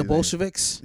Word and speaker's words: Bolsheviks: The other one is the Hart Foundation, WrestleMania 6 versus the Bolushkis. Whatever you say. – The Bolsheviks? – Yeah, the Bolsheviks.